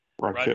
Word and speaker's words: Raquel: 26 is Raquel. Did I say that right? Rachel. – Raquel. Rodriguez. Yeah. – I just want to make sure I pronounce the first name right.